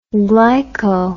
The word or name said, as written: glyco